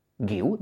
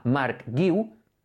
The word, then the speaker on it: Guiu: G más U más I, gui. Si le añades una U, guiu, ¿de acuerdo? Si la joven promesa del Barça se llama Marc Guiu, es exactamente igual de fácil de pronunciar que en castellano.